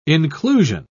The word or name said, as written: inclusion